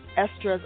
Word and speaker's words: Estra: My name is spelled E-S-T-R-A, pronounced Estra.